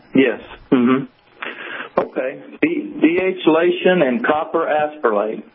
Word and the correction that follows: aspirinate: Copper aspirinate is spelled copper, C-O-P-P-E-R. Next word, okay. – A-S-P-I-R-I-N-A-T-E, like aspirin with the word eight, A-T-E on the end.